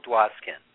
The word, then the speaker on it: Dwoskin: It's Hale Dwoskin.